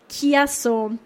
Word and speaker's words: Chiasso: Der Fall, wie spricht man es aus? Chiasso.